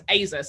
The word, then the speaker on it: ezer: But the word which I've come to pronounce Azer, some people pronounce Ezer,